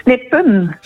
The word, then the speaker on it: De Snippen: De Snipper of De Snippen? – Snippen. – Snippen. Ja. Als één vogel De Snip en dan de meervoud De Snippen.